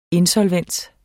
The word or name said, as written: insolvens